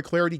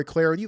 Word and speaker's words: Clardy: I'm Troy Clardy. Give me the follow on Twitter at Troy Clardy. The last name is spelled C L A R D Y at Troy Clardy.